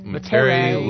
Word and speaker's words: Metairie: Metairie, Louisiana.